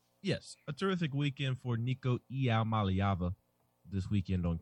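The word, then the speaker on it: Nico Iamaleava: It doesn't mean that Tennessee is going to land Nico Iaomaliava.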